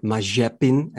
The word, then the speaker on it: Mazepin: We had three Formula 2 drivers entering the scene this season, Tsunoda, Mazepin, and or Mazepin, as it's apparently pronounced in Russian, and Schumacher.